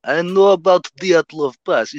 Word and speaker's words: Dyatlov: how do you say it? – Dyatlov. Dyatlov. Dyatlov Pass. Dyatlov. – Dyatlov. Dyatlov. Okay.